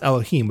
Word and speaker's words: Elohim: That God, that God there, uh, it, it, it's pronounced Elohim, which you know is plural for many gods, right? – Mm-hmm.